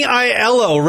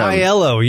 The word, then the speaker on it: Aiello: How do you say Danny Aiello, right? – Danny Aiello. Rest in peace. – Aiello. Rest in peace to him. – Aiello, yes.